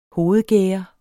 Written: hovedgærde

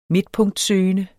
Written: midtpunktsøgende

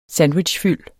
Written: sandwichfyld

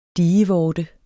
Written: dievorte

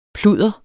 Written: pludder

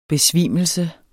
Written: besvimelse

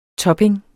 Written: topping

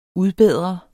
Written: udbedre

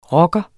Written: rocker

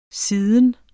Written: siden

